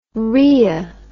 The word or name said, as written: rhoea